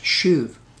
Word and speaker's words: shuv: It comes from a Hebrew word pronounced shuv. spelled S-H-U-V.